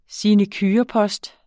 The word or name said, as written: sinecurepost